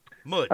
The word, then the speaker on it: Mbodj: Sheck Mudge taught us the pronunciation of M-B-O-D-J, right? Mudge.